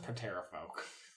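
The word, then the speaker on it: Pterafolk: She says, "Well, we just crossed over the Aldani base, and we got the attention of those dinosaur bird people, the Terra folk. They came and slashed our balloons." – Oh, but I think it's pronounced Patera folk.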